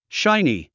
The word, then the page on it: shiny: ʃáini; シャイニィ